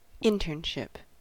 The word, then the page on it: internship: American English